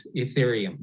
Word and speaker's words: Ethereum: The other one is Ethereum. – Right. Ethereum. That's right. And this was one of those cryptocurrencies that kind of skyrocketed in value this year amid the decentralized currency boom.